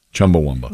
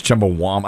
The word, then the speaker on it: Chumbawamba: Chumbawamba. – Wamba. – But look how it's spelled. The Wamba is W-A-M-B. It's Chumbawamba.